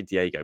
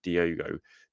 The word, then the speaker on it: Diogo: I always say Diego, but it's not, is it? It's like Diogo Jota.